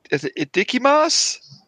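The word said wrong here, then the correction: itadakimasu: It's a dakimasu.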